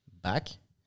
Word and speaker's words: Baak: And for us in French, Baak, B-A-A-K, and Baak, B-A-C-K, it's pronounced the same.